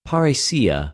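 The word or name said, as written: παρρησία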